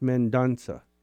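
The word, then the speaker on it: Mendonça: It's M-E-N-D-O-N-C-A, pronounced Mendonca.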